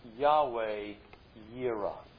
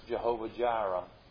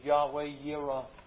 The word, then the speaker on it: Yahweh Yireh: So we call the name of the place Yahweh Yireh. Sometimes we hear it pronounced Jehovah Jireh, and there was a little song built around that. But it's literally, the Hebrew says, Yahweh Yireh.